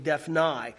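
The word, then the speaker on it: Daphnai: That's kind of how they pronounce it in the Greek, Dephnai.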